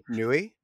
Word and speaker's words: Nui: Nui? Is that how you pronounce it? – Yeah, Nui. – Nui, there we go. That's how you say it.